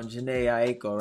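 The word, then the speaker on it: Jhené Aiko: Yeah, it's like okay, you know when you hear Janae Aiko, right? That's how you say her name.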